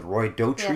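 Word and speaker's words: Roy Dotrice: And he loves his cold father, the Roy Daughtry, yes. is how you say his name, character.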